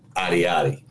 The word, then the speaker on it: Ayoade: Because right now in my head, it is saying Richard Ayoade. – I'm seeing, okay, that's what you're seeing in your head, but that's what I'm reading. – That's what it says. Okay. That's how it's pronounced.